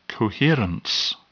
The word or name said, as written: coherence